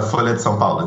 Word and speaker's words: Folha de São Paulo: It's a Folha de São Paulo.